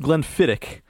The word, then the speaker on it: Glenfiddich: Well, it's pronounced Glenfiddich.